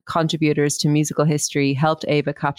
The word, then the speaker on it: AIVA: I was reading a paper about I'd pronounce it AIVA, A-I-V-A, which is it was set up in 2016.